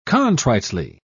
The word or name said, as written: contritely